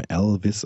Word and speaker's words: Allvis: Das ist aber auch lustig, weil da spielt Neil Gaiman auch nochmal mit, mit der, also quasi englischen Aussprache dieses Namens Elvis, also A-L-L-V-I-S geschrieben, aber eben Elvis ausgesprochen und mit dem, dass das so ähnlich klingt wie Elvis, wie, also Elvis Presley, da spielt er ja auch so ein bisschen mit.